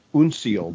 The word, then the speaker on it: uncial: Uncial, they call it. – Unical is some way some people pronounce it.